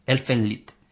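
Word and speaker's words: Elfen Lied: El nombre de Elfenlied, curiosamente, pronunciado es Elfenlied, y es alemán.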